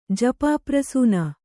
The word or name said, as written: ಜಪಾಪ್ರಸೂನ